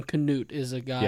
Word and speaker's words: Canute: Is that what you how you'd say it? – Canute, yes, that's how you pronounce it.